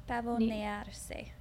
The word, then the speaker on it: pavonearse: Pavoniers? – Pavonearse. – Pavonearse? – That's it. – It's pavonearse.